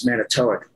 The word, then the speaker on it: Manitowoc: And they'd be from, you know, Manitowoc, whatever, which I pronounce Manitowoc, by the way, you got Wisconsinites in here.